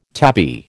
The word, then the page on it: tabby: American English